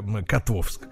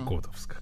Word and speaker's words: Котовск: И, кстати говоря, должен извиниться и, и ошибся с ударением, дорогие товарищи. – Котовск, конечно же, угу. не Котовск.